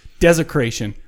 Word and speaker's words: Dezecration: All right, so this episode, we're going to have a band called Dezecration in, D-E-Z. – D-E-Z. – That's how you pronounce it. – That's how you pronounce that. Dezecration.